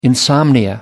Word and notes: insomnia: American English

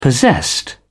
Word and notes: possessed: British English

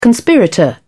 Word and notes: conspirator: British English

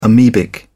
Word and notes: amebic: British English

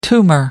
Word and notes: tumour: American English

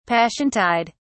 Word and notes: passiontide: British English

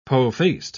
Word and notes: po-faced: British English

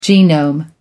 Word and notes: genome: American English

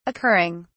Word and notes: occurring: British English

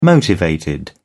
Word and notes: motivated: British English

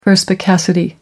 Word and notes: perspicacity: American English

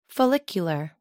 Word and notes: follicular: American English

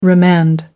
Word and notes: remand: American English

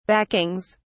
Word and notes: backings: British English

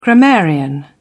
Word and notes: grammarian: British English

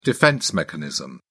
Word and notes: defense mechanism: British English

